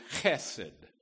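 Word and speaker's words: chesed (חסד): It's sort of a guttural, easy to spit. The, the word is chesed.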